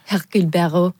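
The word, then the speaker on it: Hercule Poirot: Hercule Barreau. – täpselt, väga õige ilus hääldus, tema on ka mitte just karakter otseselt, vaid pigem juhtumid on justkui seotud ka.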